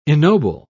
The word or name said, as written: ennoble